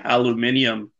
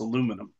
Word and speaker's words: aluminium: I mean, that's like people saying aluminium, but apparently in Europe, that's how they pronounce it. – What the fuck? – It is. That's how they teach you how to pronounce it in Europe. Here in America, it's aluminum.